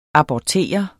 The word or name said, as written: abortere